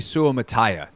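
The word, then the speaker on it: Suamataia: So we're going with Kingsley Suomataya. That's the pronunciation we have landed on.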